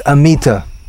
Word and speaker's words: Amita: Next one I'm going to talk about is Amita. So Amita is a common name, but it's in India it's pronounced Amit, which is, or Am.